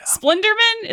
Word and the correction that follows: Slenderman: is that right? – No, Slenderman.